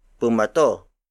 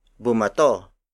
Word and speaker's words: bumato: If you add um in between the first two letters of bato, it becomes bumato. Bumato Bumato. Bumato is spelled as B U M A T O.